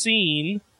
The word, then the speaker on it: Sin: I'll get to him later, but we press on with Sin, pronounced Scene, Kara, and Airborne in a high-flying match to show us why Flying Brian Pillman is still rolling in his grave.